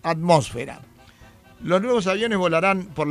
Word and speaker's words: atmósfera: De todas ellas, solo la palabra atmósfera es esdrújula. Las demás son graves y llevan el acento sobre la sílaba fe.